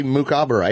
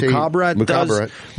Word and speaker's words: Mukhabarat: M-U-K, Mukhabarat. H-A-B-A-R-A-T. – Mukhabarat.